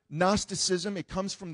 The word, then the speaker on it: Gnosticism: There was a group of people there in the city of Colossae that were really into this movement called Gnosticism. Everybody say Gnosticism. You'll see the way it's spelled on the screen in a moment. It actually begins with the word G, Gnosticism.